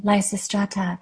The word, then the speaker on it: Lysistrata: and that's the one that that you called Lysistrata. I mm-hmm. think that's how you pronounce it. – Mm-hmm. Exactly, Lysistrata.